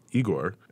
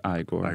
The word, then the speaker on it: Igor: was Igor, and he had the what humped line. – It's pronounced Igor.